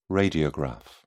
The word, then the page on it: radiograph: British English